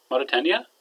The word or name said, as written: marataaniya